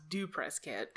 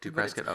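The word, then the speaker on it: presskit(): I think verbally it's still pronounced Do PressKit. – Do PressKit, okay. – It's PressKit with parentheses afterwards, like a function call.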